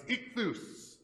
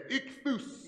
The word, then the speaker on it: ichthus: It's ichthus. Everybody turn to somebody next to you and say, ichthus.